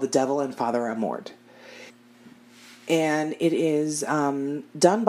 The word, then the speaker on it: Amorth: Um, and I was pronouncing his last name Amorth because that's how it's spelled. – Oh. Naturally, I was fucking wrong. – Uh, of course. – Yeah. It's actually pronounced Amort. Oh, okay. That H is silent.